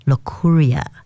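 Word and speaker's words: Locorriere: When the news broke last week that Denis Le Courrier, or I should say Le Courrier, yes, I've been practicing Le Courrier, that's how he pronounces it.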